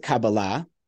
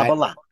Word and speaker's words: Kabbalah: So I pronounce it Kabbalah. But Kabbalah. I'm open to different pronunciations. That's sort of a more modern Hebrew pronunciation. Kabbalah is more Ashkenazi, from sort of the Eastern European Jewry.